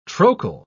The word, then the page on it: trochal: British English